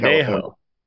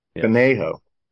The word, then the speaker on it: Conejo: And then Jordan from I don't know how to pronounce this, but it's Conejo Valley. That's a Conejo. – Conejo.